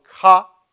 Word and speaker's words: Kha: He uses a very, very funny name in Sanskrit used in yoga, which is called Kha.